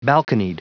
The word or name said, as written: balconied